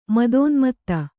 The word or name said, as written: ಮದೋನ್ಮತ್ತ